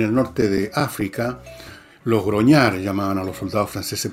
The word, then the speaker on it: grognard: O sea, la imagen, si ustedes ven en Google, imágenes de los groñar, pueden poner la palabra grognard, se escribe,